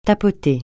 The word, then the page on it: tapoter: francais